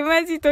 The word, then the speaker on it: 本気: 松田さん、本気と書いてマジと読むは笑う。